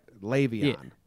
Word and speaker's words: Le'Veon: No, I just watched a clip of him yelling at Colin Cowherd to figure out how to say it right. Le'Veon. – Yeah. Yeah. – Like, lay me on the bed.